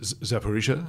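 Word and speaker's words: Zaporizhzhia: Is it Zaporizhia? Yeah.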